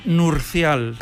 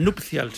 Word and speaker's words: nupcial: Sí, es que yo digo que hasta los 13 años pensé que era nurcial. – Nurcial. – ¿Ah, sí? – Y claro, de me cuesta decir nupcial, que lo he dicho mal. – Has dicho nupcial. – He dicho nupcial. Nupcial, sí, sí.